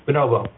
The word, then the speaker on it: bonobo: And am I saying it right, Bonobo, or? – Bonobo. – Bonobo, okay. – Yeah, you had it right.